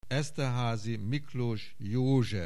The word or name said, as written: Esterhazy Miklos Jozsef